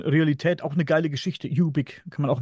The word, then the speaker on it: Ubik: Ubik ausgesprochen, UBIK.